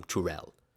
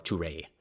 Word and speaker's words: Tourelle: The original name of a boulevard is a kind of rampart, a heavily fortified turreted bridge to something called La um, Tourelle or Tourelle depending on how the L is pronounced in French.